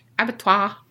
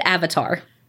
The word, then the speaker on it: abattoir: Abattoir. – You said avatar. That's the little blue guys.